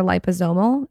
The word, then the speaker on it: liposomal: I really like how their supplements are liposomal. Is that how you say it, Michael? – Yes, that's how you say it.